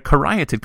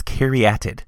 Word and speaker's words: caryatid: Okay, next up we've got the caryatid column, and I know I called it a caryatid column, but apparently I've been saying it wrong the whole time. It's caryatid.